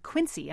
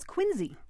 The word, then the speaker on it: Quincy: One quibble with this is that the narrator mispronounces Quincy. But I was going to ask you about that, because last time you and I listened to this ad, they said Quincy, and I thought, it's Quincy.